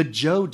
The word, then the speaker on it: Zhou: China is on the front end of what's called its Warring States period, which will end with the end of the, and I had to look up how to pronounce this, the Zhou Dynasty, which is spelled Z-H-O-U, the Zhou Dynasty.